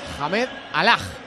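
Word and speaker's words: Hamdallah: ¿Cómo hemos pronunciado de mal? – El pueblo se pone en pie para recibir a este hombre. – Hamed Alaj. – Hamed Alaj. – Alaj. – Hamed Alaj. Ok.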